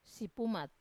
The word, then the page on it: sipumat: Wamesa